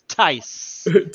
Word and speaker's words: Theiss: Yeah, you thought it was Thies. There you go, guys. If anyone ever had that question, it is Tice.